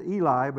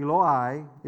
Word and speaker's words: Eloi: But it's said Eli, but it's actually Eloi, Eloi, Eloi.